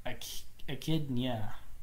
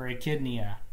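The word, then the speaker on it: Echyd'nya: They are echidnia. They are echidnas. Or echidnia. It is spelled ECHYD apostrophe NYA. And these are not echidnas, they are echidnias, and they are an alien species.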